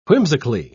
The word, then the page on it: whimsically: British English